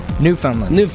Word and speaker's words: Newfoundland: How do you say it? You say it real fast. Newfoundland.